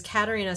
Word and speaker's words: Katarina: So it's Katarina spelled like catering with an A at the end.